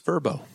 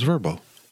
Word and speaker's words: Vrbo: It's VRBO. – It's Verbo. – It's Verbo. – Is it? – Yeah. Tim Tebow's in a commercial. – It's because there's rhymes with Tebow.